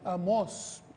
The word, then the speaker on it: Amós: No, Amós, tiene un acento en la O.